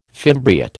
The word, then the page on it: fimbriate: American English